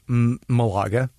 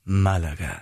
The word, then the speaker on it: Malaga: Well, yeah, in English, Malaga, right? In Spanish, Malaga.